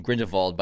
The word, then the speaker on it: Grindelwald: And I believe it's pronounced both Grindelwald and Grindelwald by different characters in the film. So I think either pronunciation is fine.